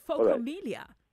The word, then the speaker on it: phocomelia: Is it phocomelia? – Yes, that's correct.